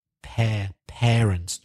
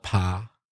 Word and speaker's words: parents: Also, a classic Spanish mispronunciation, parents. The first A is pronounced parents, not pa, parents.